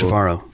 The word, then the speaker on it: Trevorrow: Is it Trevorrow or Trevorrow? – It's Trevorrow.